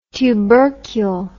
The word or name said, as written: tubercul